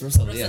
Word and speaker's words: Rosalía: It's Rosalía. Rosalía. Yeah, there's an accent on the I, I think.